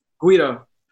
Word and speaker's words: Guido: Guido.